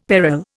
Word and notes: peril: Indian

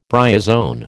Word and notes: bryozoan: American English

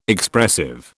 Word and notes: Expressive: British English

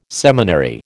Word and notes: seminary: American English

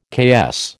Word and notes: KS: American English

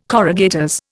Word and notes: corrugators: Indian

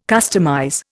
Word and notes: customize: Indian

